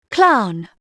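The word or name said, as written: clown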